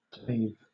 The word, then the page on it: clave: British English